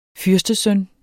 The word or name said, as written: fyrstesøn